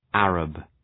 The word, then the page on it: Arab: ‘ærəb